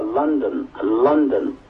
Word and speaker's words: London: 第一个来是 L 啊，L 的标准解法是 Lima 啊，非标有两个，一个是英国首都伦敦 London 啊，大家就一定要注意啊，伦敦不是照咱们汉语的说法叫伦敦，那不叫英语，这个正确读音应该叫 London 啊。